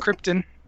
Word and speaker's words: Krypton: Marlon Brando plays Jor-El, and apparently he cannot pronounce Krypton. It is Krypton.